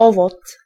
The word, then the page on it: owoc: [ˈɔvɔt͡s]